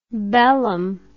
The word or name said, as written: bellum